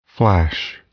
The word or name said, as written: flash